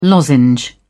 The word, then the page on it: lozenge: British English